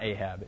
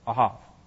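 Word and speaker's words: Ahav (אהב): It's not Ahab, it's Ahav. It's pronounced Ahav.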